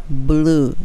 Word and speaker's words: blew: この blow これの過去形が blue になるので発音としたら b なので唇入れてしまってそして l が来るので b b b